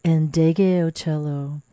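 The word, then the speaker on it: Ndegeocello: Today's program, we're exploring the life and the music of the American singer-songwriter Michel Ndege Otello. If you're curious how to spell that, it's N-D-E-G-E-O-C-E-L-L-O, Ndege Otello.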